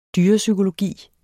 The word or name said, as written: dyrepsykologi